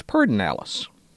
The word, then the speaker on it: Pedernales: Then there is the Pedernales River, an extra R placed in the first syllable that is not in the actual word, so it's Pedernales.